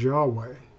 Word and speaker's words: Yahweh: The name of God, as revealed to Moses in Exodus 3:14, is YHWH, or what we pronounce as Yahweh.